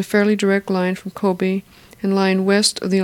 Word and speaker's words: Kobe: At that time it was called Kobe, K-O-B-E, or that's how they pronounce it.